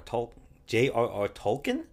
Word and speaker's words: Tolkien: Tolkien? Is that it? – Yeah, that's how you say it.